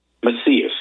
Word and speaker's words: Macias: This is by Amanda Macias, I think you pronounce it, M-A-C-I-A-S, at Fox Business.